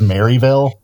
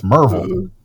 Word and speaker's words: Maryville: But he says Maryville, and it's like, oh no, man! Like that's nobody from around there actually pronounces it. It's Merville, right?